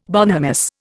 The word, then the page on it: bonhomous: Indian